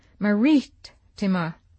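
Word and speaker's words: Marittima: The accent I find is on Maritima. I've said Maritima. Will I ever, ever learn Italian?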